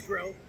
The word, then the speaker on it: Grell: Am I pronouncing it right? – It's Grell. – Grell. So you... – Rhymes with go to hell.